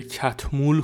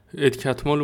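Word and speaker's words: Catmull: بعد صد نفر اومدن اسمش رو همونجا تلفظ مجریه نشسته میگه خب امروز آقای ادکتمال پیش ماست از این داستان ها کسی نمیگه ادکتمول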